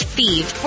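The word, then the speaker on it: Fives: like the number, but pronounced Thieve.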